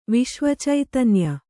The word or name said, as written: ವಿಶ್ವಚೈತನ್ಯ